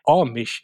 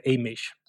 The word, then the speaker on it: Amish: Men som sagt, Benno har ett stort engagemang och jag förstår en stor kunskap om det, och vi ser genast till och rätta oss och uttala det korrekta uttalet med Amish istället för Amish.